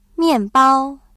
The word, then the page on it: 面包: miànbāo